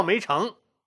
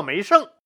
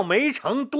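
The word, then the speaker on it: 枚乘: 对了，这个梅胜也有人管他叫梅城。这是个多音字，但综合了历代学者的文章分析，认为他的名字应该读作梅胜。再说了，叫梅成多难听，啥事儿都没办成。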